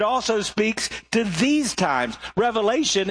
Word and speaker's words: Revelation: Now, when people hear about Revelation, and by the way, that is the correct pronunciation. There's not an S on the end. I grew up in the South, so everybody said, I want to hear a message from Revelations. No, it's just the revelation.